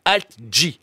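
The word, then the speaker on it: alt-J: et l'autre s'appelle Alt G. ALT plus loin J.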